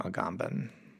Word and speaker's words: Agamben: One, am I pronouncing that correctly? And two, at what point in his career did people just start calling him by one name, Agamben? – Yes, you are pronouncing it correctly.